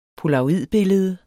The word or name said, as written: polaroidbillede